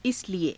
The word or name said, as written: isalie